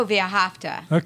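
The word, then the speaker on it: V'ahavta: It starts with a V, A-H-A-V-T-A. How do you pronounce that word? – Oh, Viahafta.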